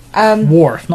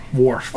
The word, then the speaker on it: Worf: They've got Wolf in it. – Worf, not Wolf. – Wolf. – Commander Wolf. – I always say it wrong. – Worf.